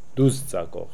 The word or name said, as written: Dooczako